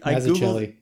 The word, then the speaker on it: Mazzucchelli: I'm really curious if that art sort of theming came from Miller or came from Mazzucchelli. I can't say his name. Is it Mazzucchelli? How do you say his name? – I Mazzucchelli.